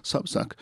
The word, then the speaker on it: Substack: Trovi anche questo podcast su Substack, scritto proprio Substack, si pronuncia ovviamente Substack,